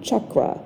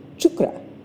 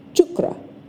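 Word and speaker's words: chukra (चक्र): And that's okay, but the Sanskrit pronunciation of the word is actually chukra, chukra. So when you hear chakra, it's kind of a blend of the two, the Sanskrit pronunciation and the American English pronunciation.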